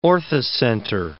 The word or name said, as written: orthocenter